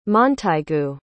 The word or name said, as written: montaigu